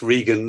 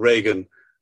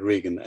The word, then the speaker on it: Regan: Just Sam, if I may, please don't take it as a rebuke, but it's Reagan, not Reagan. I hope you'll understand the historic significance of why I'm sometimes sensitive about the way people pronounce my surname, but it's Bernard Reagan.